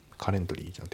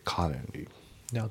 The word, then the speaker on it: currently: であと発音ですけどカレントの T が抜けますね。カレンリーカレントリーじゃなくてカレンリーで、あとワーキングオンのところがリンキングでワーキングオン、ね、ワーキングオンじゃなくてワーキングオン、あとオンアンのところもリンキングでアーナンオンアンじゃなくてアーナンですね。